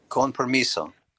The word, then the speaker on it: Con Permiso: Con Permiso? – Con Permiso.